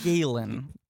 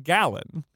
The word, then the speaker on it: Galen: I said Galen. – You said Gallen.